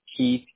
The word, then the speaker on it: Keith: Keith, K-E-I-T-H.